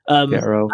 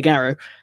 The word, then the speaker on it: Gauro: you play as Cahal, and he is a, I don't know how you pronounce, G-A-U-R-O, that basically he's a werewolf. – Garrow. – And, yeah, Garrow.